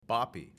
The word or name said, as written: BAPI